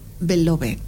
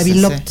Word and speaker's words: Beloved: Entonces la novela se llama, se escribe Beloved. – Beloved. – Ah, se pronuncia Beloved. – Sí, sí, sí.